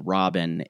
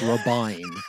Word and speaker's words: Robyn: We have, when we cover Sister Wives, we're very clear, though, that it's Robin, not Robin, because it's spelled R-O-B-Y-N, and that's Robin. – Robin. – If her name were Robin, it would be spelled R-O-B-I-N.